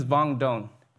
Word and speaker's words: Vuong Doan: My name is Vuong Doan. It's close enough to rhyme with Vuong Dong Ching Chong, Ling Long, ting tong ding dong, right?